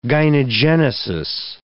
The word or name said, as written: gynogenesis